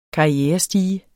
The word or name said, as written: karrierestige